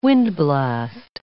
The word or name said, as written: windblast